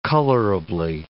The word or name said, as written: colorably